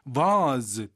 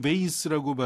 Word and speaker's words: vase: 근데 여기선 조심할 거는 vase 또는 vase라고 발음하는 거. vase가 영국 호주 발음이죠.